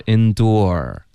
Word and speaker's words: endure: Endure 再加儿化音，后面 endear 是一个爹加儿化音。